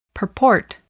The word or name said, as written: purport